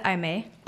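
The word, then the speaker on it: Aimee: I have a really good friend who pronounces it I-may.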